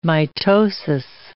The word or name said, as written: mitosis